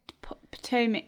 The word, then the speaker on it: Potomac: Oh, Potomac. I said it right, right? – Potomac.